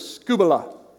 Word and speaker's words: skubala: In the Greek, that word is skubala.